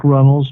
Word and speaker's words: Runles: You know, you can just put in Lies People Tell, Frank Runnels. My last name is spelled R-U-N-L-E-S.